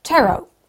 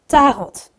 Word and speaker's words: tarot: En daar zeiden mensen tarot. Met dus de klemtoon op de eerste lettergreep. En eh, zonder de T. Zelf vertaalde ik dat naar het Nederlands als tarot.